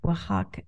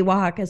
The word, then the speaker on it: Oaxaca: And Oaxaca is spelled like O-X-A-C-A, something crazy like that. But they spelled it phonetically W-H-A-K-A, I think. But people knew how to pronounce it. W-A, Oaxaca, whatever.